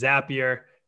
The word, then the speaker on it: Zapier: Well, again, Greg, really enjoyed the conversation, learning a little bit more about establishing product marketing at Zapier, which is pronounced correctly. It is Zapier.